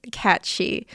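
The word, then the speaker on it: Cat Sìth: Going back in time a little bit now, in Celtic mythology, the cat she, I think it's called the cat she, it's spelled like cat Sith, which is really funny to me, like a cat Sith lord. but I do believe it is pronounced cat she, like banshee.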